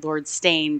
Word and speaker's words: Steyne: Well, and I mean, like with the real antagonist of the story, Lord Stain, which I is hilarious to me when they pronounced it Lord Stain, because it's spelled Steyne, but it's in my head.